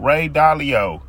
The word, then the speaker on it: Ray Dalio: So I really just be opening my mind up to new things and new ways of thinking man, Ray Diallo, Ray Dalio. I think I said his name right.